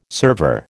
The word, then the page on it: server: American English